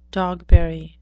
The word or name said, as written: dogberry